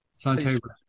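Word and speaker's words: Son Tay: Sante. – How do you spell that? – S-O-N. – S-O-N. – T-A-Y. – T-A-Y.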